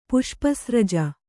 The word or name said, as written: ಪುಷ್ಪಸ್ರಜ